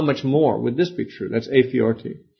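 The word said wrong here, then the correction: a fortiori: Or fortiori, excuse me.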